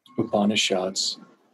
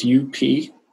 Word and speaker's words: Upanishads: Upanishads. Well, it's Upanishads.